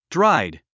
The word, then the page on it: dried: dráid; ドラァイド